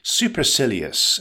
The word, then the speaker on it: supercilious: Supercilious, emphasize the third syllable, it's pronounced supercilious.